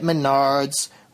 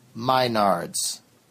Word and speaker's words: Menards: with the theme Save Big Money at Menards, which I like to sing and mispronounce as Minards. Save Big Money at Minards.